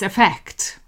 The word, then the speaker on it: effect: The pronunciation of affect with an A and effect with an E is the same. So it's effect. It's a weak form at the start. So it's a sound.